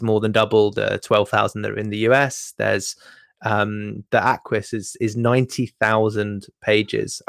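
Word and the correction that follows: acquis: I would actually I think you'll find it's pronounced Aki.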